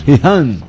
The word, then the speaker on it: Gijón: Y decías, Nacho, ¿cómo escribían Gijón? ¿Cómo era? – H-E-E-H-A-N. – Hombre, el de venir tantas veces... Gijón.